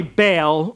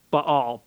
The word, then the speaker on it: Baal: Now, I pronounced a name Baal, and I think I got some weird looks. We sometimes say Baal. Well, that's okay. Say Baal if that's more normal. I think properly it's Baal.